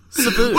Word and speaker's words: Sabu: Now, listeners won't know, but I did butcher a pronunciation of Sabu earlier to comical. – You did it again! You just did it then! Sabu.